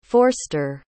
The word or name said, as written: forster